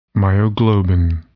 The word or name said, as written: myoglobin